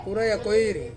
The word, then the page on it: uraia koiri: Ansus